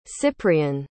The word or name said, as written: cyprian